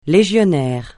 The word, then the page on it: légionnaire: francais